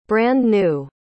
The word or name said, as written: brand-new